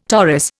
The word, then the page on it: Taurus: Indian